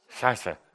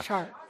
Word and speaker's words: Chartres: Yeah, we're going to go to, I wish Alain was here to pronounce it, Chartres? – Chartres. – Oh, yeah. – Chartres.